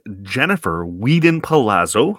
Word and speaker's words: Jennifer Weedon Palazzo: As promised, my guest today is Jennifer Weedon Palazzo.